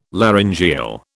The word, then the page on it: laryngeal: British English